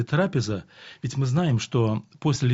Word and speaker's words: трапеза: Если мы с вами обратимся к словарю, то с греческого слова «трапеза», а именно ударение делается на «а», «трапеза», означает «стол».